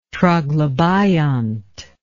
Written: troglobiont

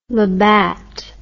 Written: labatt

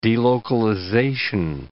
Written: delocalization

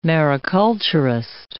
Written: mariculturist

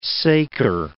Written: saker

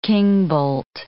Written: kingbolt

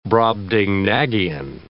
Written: Brobdingnagian

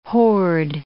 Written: hoard

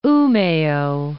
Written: Umea

